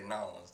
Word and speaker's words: New Orleans: they roll. They, they say Nolans down there.